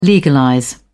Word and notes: legalize: British English

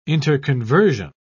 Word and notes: interconversion: British English

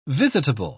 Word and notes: visitable: British English

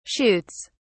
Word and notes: shoots: British English

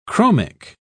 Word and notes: chromic: British English

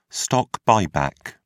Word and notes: stock buyback: British English

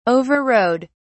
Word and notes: overrode: British English

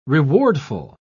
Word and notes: rewardful: British English

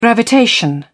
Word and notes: gravitation: British English

